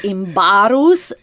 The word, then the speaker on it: Imbarus: And then my last name could not even be pronounced, even if it's Imbarus. And yes. – I'm like, well, honey, the way you spell it, that's the way you pronounce it. You don't have to twist your tongue here. But I mean, in my case, it's just another laughable situation, you know.